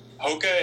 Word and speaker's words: Hoka: Hoka is the name of the brand. – How do you spell that? – H-O-K-A.